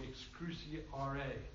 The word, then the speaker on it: excruciare: And it's spelled C-R-U-X, but it's pronounced crooks. It really means, the Latin term is excruciatus or excruciare. Did I get that right? Spot on.